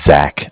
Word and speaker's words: Zach: My name is Zach, spelled Z-A-C-H. And okay. I'm calling from Bank of Cardiff. And Cardiff is spelled C-A-R like car, D as in dog, I as in image, F-F as in friend.